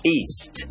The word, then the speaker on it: East: ทักทายง่ายๆสไตล์ฝรั่งไม่มีเมล็ดสวัสดีครับผมพูดไปแล้วว่า North คือทิศเหนือ South คือทิศใต้ถ้าอย่างนั้นคงพลาดคำต่อไปนี้ไม่ได้เลยครับ East E-A-S-T East หมายถึงทิศตะวันออกครับออกเสียงให้ถูกนะครับ East เสียงสุดท้ายคือ S แล้วก็ T ครับ